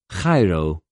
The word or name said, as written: χαιρω